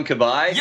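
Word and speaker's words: Cabaye: Yeah, his first name rhymes with Oh Hi. – Boyce? – Boyce, yes, Boyce. – Johan Kabay? – You are correct, sir.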